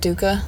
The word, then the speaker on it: dukkah: Do you have Duca? – What is... – It's from Trader Joe's. It's spelled D-U-K-K-A-H.